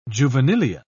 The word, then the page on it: juvenilia: British English